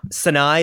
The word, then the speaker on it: Sinai: That might be my more Hebraic roots making me mispronounce something, but I believe it's Sinai. Anyway, Sinai, is that it? – Yeah.